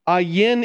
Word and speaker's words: Ayin: Ayin is keep in mind that ah is should be kind of throaty, the Ayin. Is the term, it's how it's pronounced.